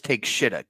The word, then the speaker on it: Takeshita: Well, you mispronounced the final guy's name, just so we all know. It's Takeshita. Get it right. – Takeshita. – Takeshita. I mean, look at how it's spelled.